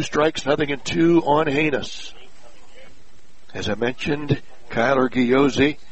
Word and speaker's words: Hanes: Very good bunter is Haynes, spelled H-A-N-E-S, pronounced Haynes.